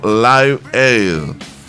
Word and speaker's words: Live Aid: La elección de esta fecha fue para conmemorar el mega concierto benéfico Live Aid. – Sí, así lo dijiste bien.